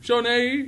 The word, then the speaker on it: Shawnee: he points the finger at the tractor company, tractor supply company in Shawnee in Oklahoma. – Shawnee, that's how you pronounce it.